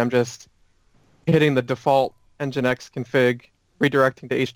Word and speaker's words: Nginx: Nginx.